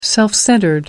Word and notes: self-centred: American English

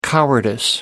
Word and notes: cowardice: British English

